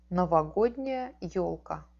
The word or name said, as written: novogodniaia elka